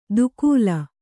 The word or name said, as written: ದುಕೂಲ